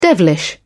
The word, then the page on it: devilish: British English